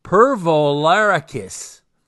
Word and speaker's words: Pervolarakis: Mikhail Perlovarik. Wait. Pervolakoris and John Millman for Australia. Man, I stumble over that young kid's name every single time. Mikhail, I am so sorry. I cannot say his name. Pervolarakis. Thank you.